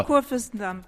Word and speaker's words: Kurfürstendamm: Nous sommes toujours à Berlin, à l'Institut français sur le Kurfürstendamm. Je ne sais pas si je le prononce bien. Presque. – Dites-le pour moi. – Kurfürstendamm. – Ah, j'étais pas loin.